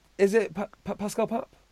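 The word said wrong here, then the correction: Pascal Papé: Or whatever his name is? – Yes, it's Pascal Pape. – Oh, I thought I butchered that.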